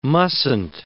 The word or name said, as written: mustn't